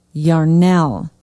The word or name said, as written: yarnell